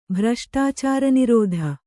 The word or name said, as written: ಭ್ರಷ್ಟಾಚಾರನಿರೋಧ